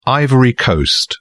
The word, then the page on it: ivory coast: British English